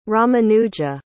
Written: Ramanuja

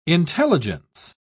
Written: intelligence

